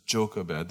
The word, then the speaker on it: Jochebed: It's not a joke, but the emphasis is Jochebed. That's how you pronounce it.